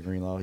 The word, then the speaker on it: Greenlaw: Dre Greenlaw, yep.